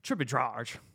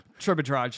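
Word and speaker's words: Tribidrag: Ends in a G. Probably a hard G. So kind of like, you know, we had Dragic on our team. You pronounce that G real hard. So Tribodrag.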